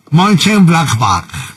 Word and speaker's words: Mönchengladbach: Del Mönchengladbach, así exacto, así como lo pronunciaría, así es, así va el alemán. – Mönchengladbach.